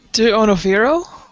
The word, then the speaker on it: D'Onofrio: D'Onofrio? – Wow, that's way better.